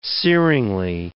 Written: searingly

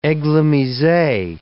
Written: eglomise

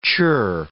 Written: churr

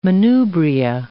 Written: manubria

